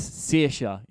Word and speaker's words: Saoirse: The way that Saoirse Ronan, it's like Saoirse. It's it's how it's spelt, but it's Saoirse.